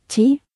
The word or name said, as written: 10